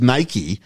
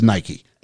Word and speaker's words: Nike: The movie, actually, when we talk about it, the movie clearly tells us it is Nike. – Yeah, but I will never pronounce it Nike because it's not a fucking bikey.